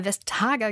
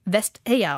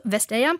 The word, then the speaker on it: Vestager: Wir haben sie bisher immer Vestager genannt, aber sie selber sagt jetzt, eigentlich wird sie Vestager ausgesprochen. Also Vestager, unsere Korrespondentin in Brüssel ist Bettina Klein und sie hört schon die ganze Zeit mit, Frau Klein.